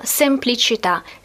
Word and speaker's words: semplicità: Alla, in fondo alla, semplicità. Semplicità, простота. Semplicità. Ударение на последний слог в данном существительном, в данном слове. Semplicità, felicità. В итальянском много таких слов с окончанием женского рода «а» с ударением на «а».